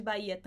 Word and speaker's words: Bahía: O H de Bahia também não é pronunciado.